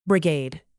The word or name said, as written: brigade